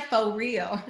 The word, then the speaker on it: Faux Real: Am I saying it right? Faux Real. – You are.